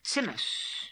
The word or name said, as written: Tzimus